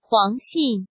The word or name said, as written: Huang Xin